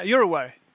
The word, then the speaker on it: Uruguay: Yeah, Uruguay.